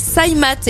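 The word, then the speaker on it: Cymath: Le quatrième, ça s'appelle SciMath.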